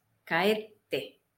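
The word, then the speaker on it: kaette (帰って): Kaeri más kaette. Hay su pequeña te. Y la pronunciación tiene como si tuviese un mini acento antes del te.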